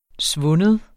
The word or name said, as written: svunden